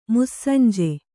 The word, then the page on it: ಮುಸ್ಸಂಜೆ: mussanje